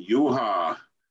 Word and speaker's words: Juha: And my name, Juha, it was called Yeehaw all across the Midwest.